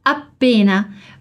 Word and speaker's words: appena: Aqui a pronúncia tá tranquila, você só precisa alongar um pouquinho o som do appena.